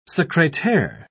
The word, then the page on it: secretaire: British English